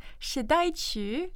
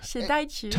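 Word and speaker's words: Shidai Chu (時代曲): And you helped me pronounce it before we were going on the air. It's Shidai Chu? – Shidai Chu. Shidai Chu.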